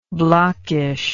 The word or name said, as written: blockishness